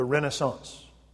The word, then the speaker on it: Renaissance: In France, it's pronounced like this, the Renaissance.